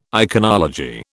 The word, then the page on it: iconology: British English